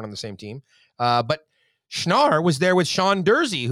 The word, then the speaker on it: Schnarr: S-C-H-N-A-R-R has to be Schnarr. – That's Schnarr, yeah.